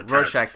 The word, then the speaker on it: Rorschach: It's like a book that's like a Rorschach, I can't even pronounce it. – Yeah, Rorschach test.